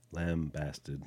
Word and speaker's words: lambasted: I actually told Missy about the land blasting versus... – Lambasted. – Lambasted.